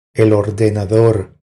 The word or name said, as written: El ordenador